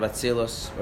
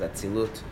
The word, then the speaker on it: Atzilus: Why would it say Atzilut here, but we pronounce it Atzilus? – Because it has a tough stuff at the end, and the translator decided to go with the tough pronunciation, but being Ashkenazi, I'm going to say Atzilus.